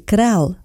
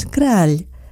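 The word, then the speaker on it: Kráľ: En eslovaco muchos pronuncian mal el apellido de este poeta romántico, y es que Kral no es Kral, es decir, el rey, algo que este poeta sin duda fue.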